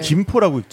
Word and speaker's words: 김포: 그러니까 한자로는 쇠금자를 쓰지 않나요? 그러면 금포라고 읽어야 되는데 우리 성씨 읽는 것처럼 김포라고 읽잖아요.